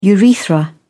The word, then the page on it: urethra: British English